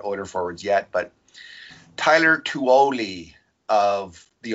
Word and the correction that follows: Tullio: Oshawa Generals, who I always mispronounce as something. Just did again. – Well, what is it? What is it? Is it not Tuoli? – Tulio. T-U-L-L-I-O.